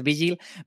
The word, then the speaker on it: Vigil: Venga, vamos con el número 10, que es Vigil, o Vigil, que como lo pronunciamos nosotros, pero en realidad es Vigil.